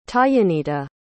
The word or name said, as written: tayanita